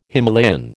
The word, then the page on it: Himalayan: American English